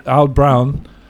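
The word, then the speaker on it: Oud Bruin: And the one for this one, the Oud Brown, which that's the Flemish pronunciation, that's the typical pronunciation, or, you know, Vie Brun, which is French, it had a picture of a black woman.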